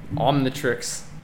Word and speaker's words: Omnitrix: Omnitrix.